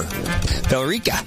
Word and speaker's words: Billerica: How do you pronounce that? There's an E in there. – Billerica. – Billerica, maybe? – Billerica. – Oh, that makes sense. Just leave out the E.